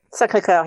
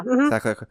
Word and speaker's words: Sacré-Cœur: Sacré-Cœur. Did I say that right? – Sacré-Cœur, yeah. – Sacré-Cœur.